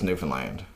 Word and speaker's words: Newfoundland: But no, the proper way to say Newfoundland is Newfoundland.